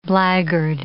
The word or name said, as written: blackguard